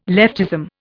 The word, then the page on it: leftism: Indian